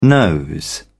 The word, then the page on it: nose: British English